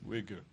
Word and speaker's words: Uyghur: Uyghur. – That's how you pronounce it.